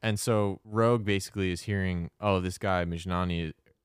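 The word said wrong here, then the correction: Mjnari: am I saying it right? – No, Mijnari. – Mijnari, oh my God. – It's like Minari with the Mij.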